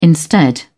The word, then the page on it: instead: British English